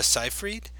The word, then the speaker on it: Seyfried: But for this, I went with Amanda Seyfried. Is that how you pronounce her name? – Okay, yep.